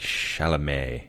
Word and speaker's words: Chalamet: And Timothy, I think Chalamet is how he pronounces Chalamet? – It.